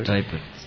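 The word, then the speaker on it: Stryper: Uno de los gallos que son súper buenos, no sé si lo pronuncio bien, pero es Stripper o Striper. – Striper.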